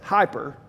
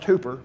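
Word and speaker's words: hooper (ὑπέρ): You can see the word at the beginning there that we have come to say as hyper, right? We've got hyper and hypo from the Greek. It's actually pronounced hooper, but that's neither here nor there.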